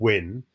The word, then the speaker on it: UYN: And it's spelled UYN, but it's actually pronounced WIN.